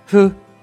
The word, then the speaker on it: ふ: 第三个假名 who 的发音比较特别，既不能读成呼吸的呼，也不能读成丈夫的夫。那么该怎么读呢？先做出 u 的口型，然后呼气并震动声带，hu hu。平假名 hu 和片假名 hu 都来源于汉字的不能的不。看一下单词，hu yu hu yu。